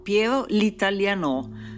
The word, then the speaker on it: Piero l'Italiano: Spesso non ha una dimora fissa, specialmente nei mesi trascorsi a Parigi, dove lo chiamano Piero l'Italiano, tutto unito e con l'accento finale alla francese, e dove sembra aver conosciuto Sartre e forse Céline.